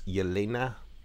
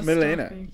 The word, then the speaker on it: Yelena: where she meets Yelena. Did you want to correct the pronunciation there? – I stopped myself. I was like, it's not Milena. – Yelena with a Y.